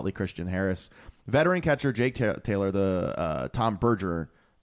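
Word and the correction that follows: Berenger: Berringer, damn it. I can't pronounce his last name.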